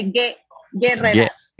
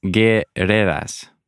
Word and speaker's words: Guerreras: But you don't pronounce the U, so you say Guerreras. – Guerreras.